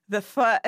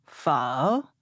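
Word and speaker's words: phở: Well, if you want to impress a Vietnamese speaker, you would say pho. – Pho. – Yeah, sort of like languidly, sort of romantic, you know.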